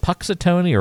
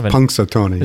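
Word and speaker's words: Punxsutawney: Is it Puxatony or Punxatony? – I think it's Punxatony. – There's like an N in there, right? – Yeah.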